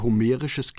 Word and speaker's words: homerisch: Ich höre homerisches Gelächter, wobei homerisch eigentlich Homer ausgesprochen gehört, wie in Homer Simpson.